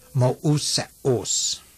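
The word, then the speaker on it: mouseos (Μωυσέως): and the vowels should be pronounced separately, like the Greek word for Moses in John 1:17, in your workbook, page 19, pronounced mouseos.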